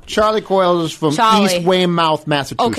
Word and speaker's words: Weymouth: It's Weymouth.